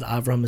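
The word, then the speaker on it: Avraham (אברהם): Avraham is the Hebrew pronunciation of Abraham.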